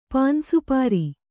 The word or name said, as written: ಪಾನ್‍ಸುಪಾರಿ